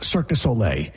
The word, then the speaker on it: Cirque du Soleil: And nobody gave him a heads up that Cirque du Soleil is pronounced Cirque du Soleil.